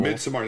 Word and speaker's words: Midsommar: Midsommar, that's how we pronounce it.